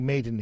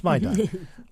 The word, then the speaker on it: Maidan: We all want to say Maiden here, but it's Maidan.